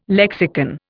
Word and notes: lexicon: Indian

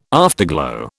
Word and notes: afterglow: British English

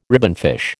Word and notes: ribbonfish: American English

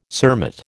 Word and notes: cermet: American English

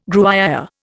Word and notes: Gruyère: Indian

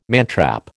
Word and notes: mantrap: American English

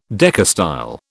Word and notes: decastyle: British English

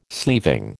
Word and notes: sleeving: American English

